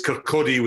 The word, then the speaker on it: Kirkcaldy: By the way, it's Kirkcaldy without pronouncing the L, John.